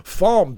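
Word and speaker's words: Falme: And he says, Falm, because it's pronounced Falm Moraine.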